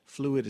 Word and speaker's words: Phluid: Fluid is spelled P-H-L-U-I-D.